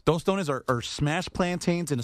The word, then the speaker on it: tostones: Yeah, if I'm going to say it like a gringo, I'm going to say tostones. – Okay. – But it's tostones.